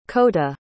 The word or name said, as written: kota